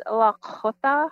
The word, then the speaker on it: Lakhota: Sorry, I'm not saying it right, am I? – Oh, it's okay. It's Lakhota. – Lakhota.